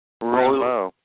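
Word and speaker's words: rouleaux: How do you pronounce that? Rolex? – Rulo. Just Rulo. – Rulo. Rulo. And it's R O U L E A U X.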